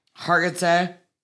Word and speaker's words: Hargitay: Hargitay. – Huh? – Mariska Hargitay.